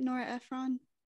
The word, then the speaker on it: Nora Ephron: Do I say that right? Nora Ephron? – Yes.